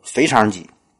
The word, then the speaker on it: 腓肠肌: 后边呢这块肌肉叫做腓肠肌，腓肠肌听着就这么有食欲。但是呢，咱们经常都会把这个字读错，嗯，有时候读成，有时候读成第一声飞，有时候读成第三声飞，这都不对。